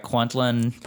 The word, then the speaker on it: Kwantlen: So you also teach improv at, am I saying this right, Kwantlen Polytechnic? – Absolutely, yeah.